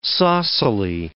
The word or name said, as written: saucily